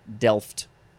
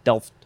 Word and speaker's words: delft: D-E-L-F-T, delft. How much do you pronounce the T? You just say delft, delft, delft.